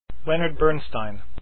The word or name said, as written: bernstein